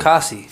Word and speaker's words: Fatukasi: Lorenzo Fukutaski. Did I say it right? – No, that was bad. That was pretty bad. – Fatukasi.